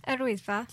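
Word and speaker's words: Yr Wyddfa: Erwidfa.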